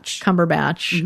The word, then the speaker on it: Cumberbatch: Cumberbatch.